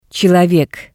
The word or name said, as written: Tselovek